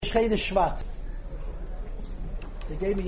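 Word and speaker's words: Shvat (שבט): He said Shvat. – And he told me to say Shvat. – Shvat. – This is technically the correct way to say it, because whenever you, there are many words that have a patach.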